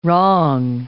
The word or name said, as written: wrong